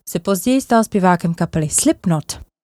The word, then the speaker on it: Slipknot: Na slipknotech je zajímavé, teda alespoň z fonetického hlediska, že K ve výslovnosti je tiché, takže správně bychom je měli vyslovovat jako slipknot. Máme ale zažité slipknot, tak proč ne?